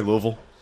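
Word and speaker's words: Louisville: Come on, that's the way they pronounce it anyway, Louville.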